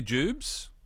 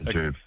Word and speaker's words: Jubes: Do locals say Jubes? – 100%. – Yeah, so it's always abbreviated to Jubes. – Never Jubilee or Jubies.